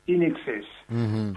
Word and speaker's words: INXS: en inglés se pronuncia INXS.